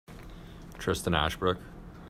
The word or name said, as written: Ashbrook